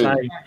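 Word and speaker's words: açaí: Acai. Um, fruit, oh, yeah. Acai. Acai.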